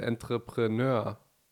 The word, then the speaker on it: Entrepreneur: Und dass diese ganzen Sachen, die negativ sind, was Selbstständigkeit mitbringt, die man nie auf dem Schirm hat, wenn man immer diese Entrepreneur heißt, habe ich richtig ausgesprochen? – Korrekt.